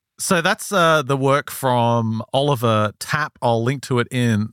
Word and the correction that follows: Tappe: the show notes, or Tapper, I should say. That would be the German pronunciation.